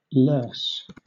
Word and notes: loess: British English

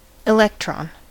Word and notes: electron: American English

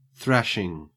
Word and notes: thrashing: Australian English

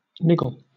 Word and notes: niggle: British English

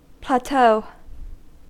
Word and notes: plateau: American English

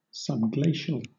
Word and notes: subglacial: British English

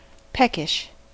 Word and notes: peckish: American English

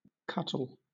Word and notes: cuttle: British English

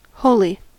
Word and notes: wholly: American English

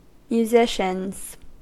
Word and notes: musicians: American English

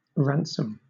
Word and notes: ransom: British English

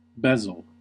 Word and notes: bezel: American English

